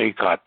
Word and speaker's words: Cicotte: and the family said it was uh, Seacott.